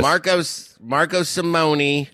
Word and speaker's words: Marco Simone: Marco Simone.